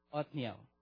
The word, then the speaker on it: Othniel: I think you can see in even Joshua chapter 15, 17 and Judges chapter 1, 13 also you can see that how is pronounced? Othniel or how to pronounce? O-T-H-I-N-I-E-L. Othniel.